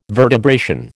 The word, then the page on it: vertebration: American English